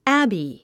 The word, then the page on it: abbey: ǽbi; アァビィ